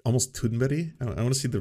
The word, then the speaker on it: Thunberg: Thunberg! Ah! Wow, of course Amy was on point.